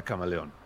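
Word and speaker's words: CM3leon: Se llama CM3 León y se pronuncia Camaleón.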